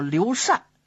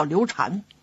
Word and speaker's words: 刘禅: 这刘禅的禅字呢，有两个读音，一个是善，一个是禅。按说呢，应该叫刘善，可是因为人们都习惯了，不管说书啊，或者舞台上啊，或者平常聊天，都说后主刘禅，我们就按习惯读音，咱们还管他叫刘禅。